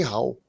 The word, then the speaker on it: Hao: Dogecoin is not a joke, says the CEO of OKX, Jay Hua. How do you pronounce that? – Jay Hao. – I don't know how to pronounce. – It's H-A-O. That would be how.